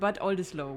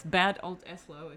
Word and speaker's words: Bad Oldesloe: It's called Bad Oldesloe, which I think outside of Bad Oldesloe, nobody knows. It's Bad Oldesloe if you pronounce it English, which is even weirder.